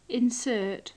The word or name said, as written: insert